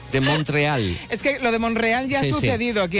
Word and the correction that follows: Montreal: Montreal. Bueno, Monreal no es. – No es bueno Monreal, porque hay una T en medio. – Sí. – Es Montreal.